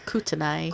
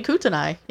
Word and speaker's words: Kootenai: And I'm going to try to pronounce this. It's called the Kootenai River. – Kootenai. – Kootenai. – How is it spelled? – Kootenai. – That sounds like right? It would be Kootenai.